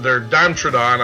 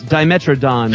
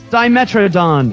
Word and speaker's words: Dimetrodon: And we just, earlier on the podcast here, we just played Tabletop from their Dimetrodon. I'm going to mess that up again. – No, that's Dimetrodon.